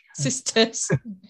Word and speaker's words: Cistercian: Cistercian.